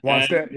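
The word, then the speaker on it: Wannstedt: Jimmy Johnson as well as Dave Wanslet. Apologies if I pronounced that wrong. – Wanslet, yep.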